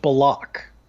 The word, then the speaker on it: Bullock: There was a whole point where he made it clear that his name is, in fact, pronounced Bullock.